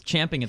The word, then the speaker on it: champing: Yeah, it's champing at the bit. – Except it is acceptable to pronounce it chomp.